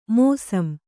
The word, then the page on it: ಮೋಸಮು: mōsamu